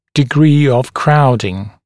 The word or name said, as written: degree of crowding